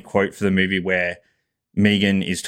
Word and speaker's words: M3GAN: I watched the 2022 horror thriller movie called Megan, but that's spelled with a 3, so M3GAN.